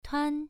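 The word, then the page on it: tuan: tone 1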